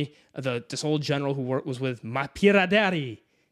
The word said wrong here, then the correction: Mapidéré: Mapidéré. Shit. okay, with Mapidéré. So he's Imagine he comes down French. Here. – That's what. Just think French. It, think French, and you'll say it right.